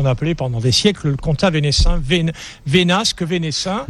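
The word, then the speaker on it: Comtat Venaissin: puisque vous signez cette nouveauté qui est le compte Venaissin, comment on prononce ? – C'est le Conta Venaissin.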